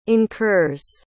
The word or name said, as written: incurs